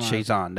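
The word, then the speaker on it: Chiasson: So I'm almost Chazé, I think that's how you say it. – I'm almost positive that's one. – Chazon, that's what it is.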